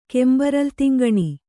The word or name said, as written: ಕೆಂಬರಲ್ತಿಂಗಣಿ